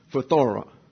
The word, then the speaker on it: phthora: These two words translate the Greek noun phthora, and it will be an important part of our study later on. Corruption and decay. Phthora actually is a correct pronunciation. That's with accents on the last syllable.